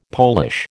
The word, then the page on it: Polish: American English